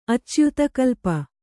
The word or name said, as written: ಅಚ್ಯುತಕಲ್ಪ